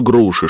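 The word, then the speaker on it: Grouchy: «Наполеон отправил ему навстречу груши, чтобы не пустить к Веллингтону». – Льюис засмеялся. «Что отправил?» «Это фамилия», — подсказала миссис Циммерман. «Только произносится она как груши. Он ведь француз.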